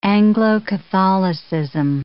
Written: Anglo-Catholicism

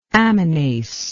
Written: aminase

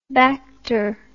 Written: bacter

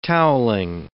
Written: toweling

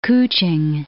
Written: Kuching

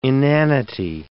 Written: inanity

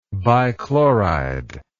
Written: bichloride

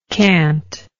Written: cantingly

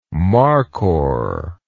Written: markhor

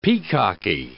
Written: peacocky

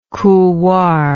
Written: couloir

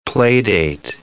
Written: playdate

